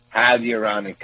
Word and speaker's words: hyaluronic: Hyaluronic acid. – Yeah, hydroonic? – No, hyaluronic.